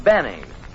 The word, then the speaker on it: Benny: No, this is Jack Benny. Rhymes with penny if he can arrange it.